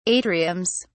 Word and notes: atriums: British English

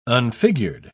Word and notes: unfigured: British English

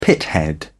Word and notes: pithead: British English

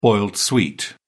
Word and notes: boiled sweet: British English

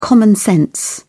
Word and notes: common sense: British English

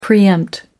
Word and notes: pre-empt: American English

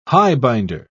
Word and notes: highbinder: British English

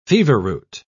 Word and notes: feverroot: British English

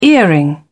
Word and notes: earring: British English

